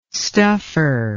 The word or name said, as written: stuffer